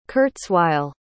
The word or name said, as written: kurzweil